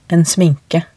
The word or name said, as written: en sminke